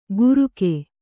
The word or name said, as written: ಗುರುಕೆ